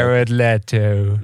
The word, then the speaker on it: Jared Leto: Blijkbaar heb ik het dus 20 jaar lang verkeerd uitgesproken. – Jared Leto. – Ja. In Lito ging je. Jij ging voor Lito.